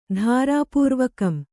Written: ಧಾರಾಪೂರ್ವಕಂ

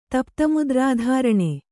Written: ತಪ್ತಮುದ್ರಾಧಾರಣೆ

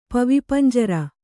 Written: ಪವಿಪಂಜರ

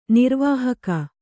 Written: ನಿರ್ವಾಹಕಿ

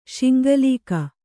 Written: ಶಿಂಗಲೀಕ

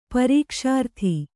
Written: ಪರೀಕ್ಷಾರ್ಥಿ

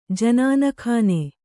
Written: ಜನಾನಖಾನೆ

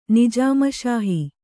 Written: ನಿಜಾಮಶಾಹಿ

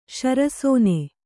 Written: ಶರಸೋನೆ

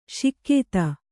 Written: ಶಿಕ್ಯಿತ

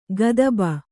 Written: ಕದುಬು

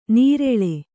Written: ನೀರೆಳೆ

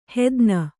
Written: ಹೆದ್ನ

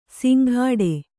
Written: ಸಿಂಘಾಡೆ

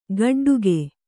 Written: ಗೋಟಡಕೆ

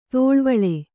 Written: ದಳವುಳ

